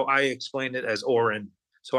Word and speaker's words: Oron: And his name I have in the book, O-R-O-N. Like, pronounced like Oren.